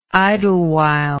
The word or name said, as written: idlewild